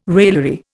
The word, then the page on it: raillery: Indian